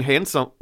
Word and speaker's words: handsome: I have the Dean handsome. No, I guess the D is silent handsome.